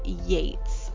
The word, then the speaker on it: Yeats: Yeats, and I think we both pronounced it wrong, and it should sound more like Yeats, so if you're bad on if you're judgy on the way people pronounce stuff, I think it's Yeats, so I did my best.